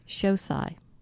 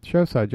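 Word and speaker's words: Xocai: Shosai. – Shosai, just how it's spelled. – What? It starts with an X.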